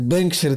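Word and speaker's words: Bengtskär: потому что оно пишется как Бендскар, произносится как Бэнксер, это такой, ну не знаю этих языков, ты немножко теряешь в этом смысле, но ну, не суть.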